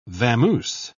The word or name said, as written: vamoose